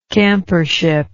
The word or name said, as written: campership